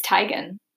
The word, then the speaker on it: Teigen: Isn't it Teigen? – It's Teigen. – Oh, people always mispronounce it.